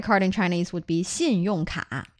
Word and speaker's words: 信用卡: Are you using two syllables there? 信用卡. – No. 卡 is one syllable.